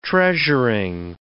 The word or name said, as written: treasuring